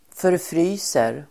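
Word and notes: förfryser: förfr'y:ser

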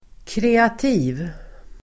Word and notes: kreativ: [kr'e:ati:v el. -ti:v]

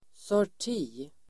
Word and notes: sorti: [sår_t'i:]

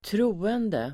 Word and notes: troende: [²tr'o:ende]

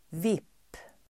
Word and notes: VIP: [vip:]

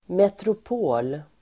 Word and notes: metropol: [metrop'o:l]

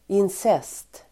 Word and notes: incest: [ins'es:t]

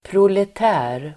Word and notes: proletär: [prolet'ä:r]

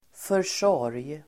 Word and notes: försorg: för_s'år:j

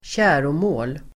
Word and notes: käromål: [²tj'ä:romå:l]

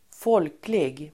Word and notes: folklig: [²f'ål:klig]

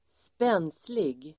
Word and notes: spenslig: [²sp'e:nslig]

